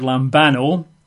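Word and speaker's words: lambano (λαμβάνω): It's a Greek word lambano. That's spelled in English letters L-A-M-B, like lamb, little lambsy divey. Lamb, L-A-M-B-A-N-O, lambano.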